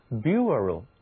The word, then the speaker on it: bureau: 好了，顺便呢再提到一个词，它是以 B-U-R 开头的，读作 bureau， bureau 其实呢这个 B-U-R 它和 B-A-R 意思很相近，表示的是木头的棚子。这个词好像很难读， bureau 听起来不像是英语发音的这种习惯。是的，它来自法语，在法语里边呢 bureau 表示的是写字台办公桌。